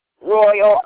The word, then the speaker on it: Royal: Royal, R-O-Y-A-L.